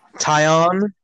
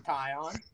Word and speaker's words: Taillon: How do you say his name? Taillon? – Wait, what? That's what I it's Toyan. It's Tanyan. Tanyan. – Tyon. – Tyon. – Yeah, that's how it's pronounced.